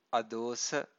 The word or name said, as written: adosa